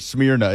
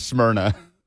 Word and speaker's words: Smyrna: Even though you pronounced it Smyrna, it's Smyrna.